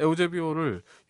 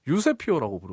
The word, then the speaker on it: 에우제비오: 북한에서는 에우제비오를 유세피오라고 부르거든요. – 아. 이름을 북한식으로 발음해서 유세피오라고 부르는데 북한에서 유세피오라는 단어는 치욕의 대명사로 통합니다.